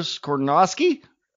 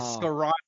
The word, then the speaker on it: Skoronski: The Las Vegas Raiders at number seven take Peter Skoronski. – Oh, you were saying it so good before we started. Skoronski.